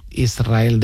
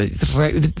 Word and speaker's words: Israel: Israel, ¿no? – Y la S con la R es difícil de pronunciar. La gente dice Israel, es verdad. – Que suene la S.